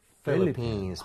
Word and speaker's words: Philippines: 여러분 PH는 F와 같은 성질이라서 진짜 발음할 때 필이 아니라 원래는 Philippines.